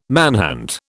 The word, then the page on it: manhunt: British English